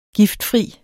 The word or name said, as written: giftfri